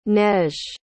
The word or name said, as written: neige